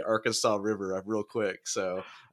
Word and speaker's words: Arkansas: It's spelled Arkansas, but they don't call it the Arkansas River. They call it the Arkansas.